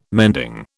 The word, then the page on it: mending: British English